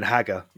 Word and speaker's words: Haggar: How's it spelled? – H-A-G-G-A-R. – Oh, then Hagar. Yeah, for sure.